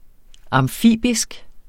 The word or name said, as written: amfibisk